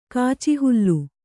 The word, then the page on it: ಕಾಚಿಹುಲ್ಲು: kāci hullu